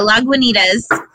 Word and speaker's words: Lagunitas: Yeah, Lagunitas.